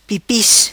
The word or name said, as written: Pipis